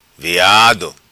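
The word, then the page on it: Veado: patua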